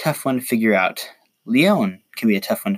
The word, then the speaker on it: Leon: Speaking of mages with decent defense, the next unit on this list is Lion, or Leon, as I have been told by the game 1,000 times it's pronounced.